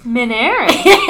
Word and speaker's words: Minarik: Minarik.